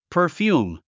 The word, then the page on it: perfume: pə́ː r fjuːm; パァヒューム